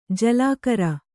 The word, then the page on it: ಜಲಗಾರ: jalagāra